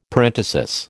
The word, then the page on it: parenthesis: American English